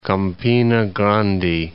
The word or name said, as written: Campina Grande